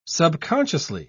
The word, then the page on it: subconsciously: British English